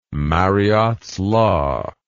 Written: mariottes law

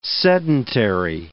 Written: sedentary

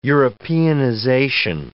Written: Europeanization

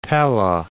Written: Pella

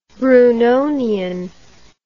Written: brunonian

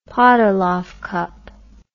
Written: Podoloff Cup

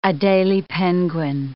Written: Adelie penguin

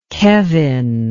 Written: kevin

